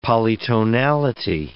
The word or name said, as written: polytonality